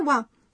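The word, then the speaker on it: wa (は): Quando se escreve a partícula wa em hiragana, usa-se o caractere ha e não wa, mas a pronúncia é wa.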